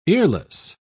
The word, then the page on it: earless: British English